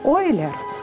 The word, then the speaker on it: Эйлер: И, например, вот биография Леонарда Эйлера, или по-немецки правильно говорить Ойлер, его фамилия, он был швейцарец из немецких северных кантонов, из Базеля, и...